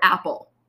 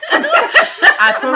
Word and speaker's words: Aperol: Please let us know if she's saying Aperol or Apple. Apple spritz. – Apple spritz. – Yeah. – Aperol. – My point exactly. – Aperol spritz. – Yeah. Three syllables.